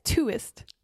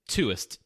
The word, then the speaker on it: Tuist: Tuist. – Tuist. T-U-I-S-T.